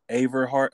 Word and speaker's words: Averhart: And also, I want to ask you, is Averhart how you say your name? – Yes.